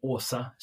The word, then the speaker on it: Åsa: her name is Awesome, not awesome, Åsa, Swedish name, but you can pronounce it right by saying awesome.